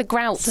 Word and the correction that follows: groats: So, grouts, is that how you say it? – Groats.